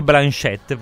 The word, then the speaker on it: Blanchett: Anzi, Kate Blanchett, perché come Laurence Olivier si pronuncia alla francese, sapevatelo.